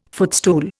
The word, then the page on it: footstool: Indian